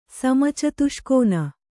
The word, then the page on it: ಸಮಚತುಷ್ಕೋನ: kannada